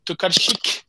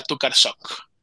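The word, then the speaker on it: Tokarczuk: o bueno, se pronuncia Olga Tukarsik. Pero pues como somos latinos y como lo he escuchado siempre en español, es Olga Tukarsok. Nosotros le ponemos la O. Entonces así es conocida en español como Tukarsok.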